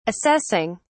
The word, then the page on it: assessing: British English